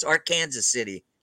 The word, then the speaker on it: Arkansas City: It's spelled like Arkansas City, but it's Arkansas City, Kansas.